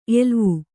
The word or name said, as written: ಎಲುವು